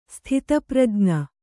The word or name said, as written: ಸ್ಥಿತಪ್ರಜ್ಞ